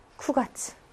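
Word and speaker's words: 쿠가츠 (九月): 얘는 쿠가츠.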